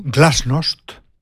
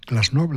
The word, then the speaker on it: glasnost: Aparatchik, esto es miembro del aparato burocrático, o glasnost, pronunciada como llana o como glasnost, la política de apertura informativa.